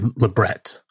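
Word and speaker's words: labret: Everybody calls it Lebret piercing. The word's actually Lebret. But if you say Lebret piercing, you pronounce it properly in French, people look at you like you're crazy. They want you to do the fake affectation of French and call it a Lebret.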